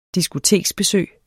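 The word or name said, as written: diskotekbesøg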